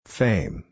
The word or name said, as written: fame